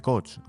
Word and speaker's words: coach: Maestro de coach, o sea, no de coche, de coach.